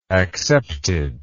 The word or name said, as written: acceptedly